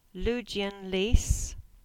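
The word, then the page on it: Ludgvan Leaze: Cornish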